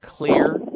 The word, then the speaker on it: Xlear: They have a nasal spray in the health food stores called Clear Nasal Spray. It's spelled X-L-E-A-R.